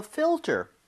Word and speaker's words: filter: F-I-L-T-E-R. 자, 근데 발음 주의하셔야 되는 게 지금 fill 할때 L 있죠? – 네. – 그리고 ter 할때 R. Right? – 와. – L-R. 이 순서로 되어 있기 때문에 네. – 혀하고 입모양이 제대로 들어가야 돼요.